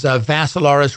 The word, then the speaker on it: Vassilaris: And the last name is Vassilaris, rhymes with Assilaris, but you add a V.